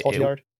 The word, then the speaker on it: Cotillard: I thought uh, Marion Cotillard, I believe is how you say her name. – Uh, Cotillard yeah, is the the actress that played Mal.